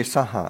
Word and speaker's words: saha: Well, it should be saha. – It should be just short. – Ti sarane na saha silani. So that it should, it's later, pronounce it properly, it'd be two short syllables.